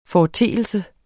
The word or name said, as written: FORETEELSES